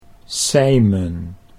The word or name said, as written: Sijmen